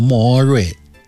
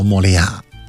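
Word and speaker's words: Moorea: 对面那个岛叫莫瑞岛，也叫莫利亚岛，因为这个它是法属波利尼西亚，人讲法语的，所以它那个名字的拼法是法语的那个读音，叫莫瑞。那么拿英语拼音拼那名字就叫莫利亚，但说的都是一回事儿。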